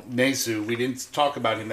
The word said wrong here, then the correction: Nezu: Um, you know, I've been saying his name wrong. It's Nazoo. It rhymes with kazoo.